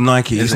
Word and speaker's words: Nike: The pronunciation is Nike. It's named after the Greek god of victory, Greek goddess of victory. And her name was pronounced Nike.